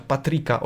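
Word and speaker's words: Patrik: Zauważają to dziennik dziennikarze e, i to dlatego Patricia wybierają ogólnie najsłabszym graczem, nie Patricia, Patrika oczywiście, Patrika, tak wy, wymawia się to nazwisko.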